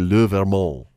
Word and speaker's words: Vermont: You would drop the T and the pronunciation would be Le Vermont.